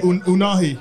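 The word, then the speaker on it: Ounahi: Like, so how you pronounce that name? Because I need to pretend that I've known him for years. How you say it? – Unahi. – Unahi, okay.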